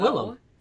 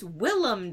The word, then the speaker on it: Willem: Christian Bale for Vice, Bradley Cooper for A Star Is Born, William Dafoe, and actually, Willem. It's not William Dafoe. – Yeah, it's Willem. – It's Willem Dafoe.